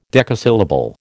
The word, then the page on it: decasyllable: American English